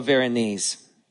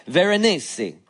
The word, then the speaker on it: Veronese: And um, I tell the story in that chapter um, about the Italian painter uh, Paolo Veronese. That's the southern way of pronouncing that name. Veronese. There you go.